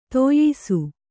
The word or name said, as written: ದೋಯಿಸ